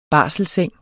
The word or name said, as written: barselsseng